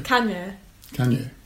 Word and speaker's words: Kanye: How do we pronounce Kanye? – Kanye. Yeah, that was fine.